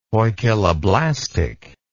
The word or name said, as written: poikiloblastic